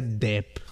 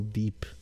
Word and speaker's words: Depp: Se pronuncia Depp, no Deep, porque he escuchado varias personas que hasta el día de hoy, a fecha del día de hoy, le siguen diciendo Deep.